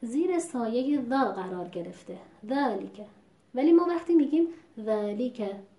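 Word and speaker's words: ذٰلِكَ: مثلا در کلمه ذالیکه، الان این رو با رعایت حق و مستحق حرف ما درست تلفظ کردیم، ذالیکه، یعنی علاوه بر مخرج حرف ذا درست تلفظ کردن استفال و بعد حرف لام و حرف کاف و در این حال وزن کلمه. خب ممکنه که بر اساس عادت تلاوت‌های قبلیمون بخونیم ذالیکه. خب در اینجا دیده میشه که در تلفظ حرف لام و کسره‌ای که این حرف داره اختلاس دیده میشه،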